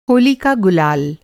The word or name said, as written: गुलाल